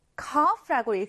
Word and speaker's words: cough: 이 기침은 cough, cough라고 읽습니다. C-O-U-G-H인데 F 발음이 난다는 점을 주의해 주세요.